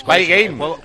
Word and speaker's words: Spy Game: Buena, la de Space Game. – ¿Sabes? Spy Game.